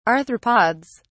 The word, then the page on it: arthropods: British English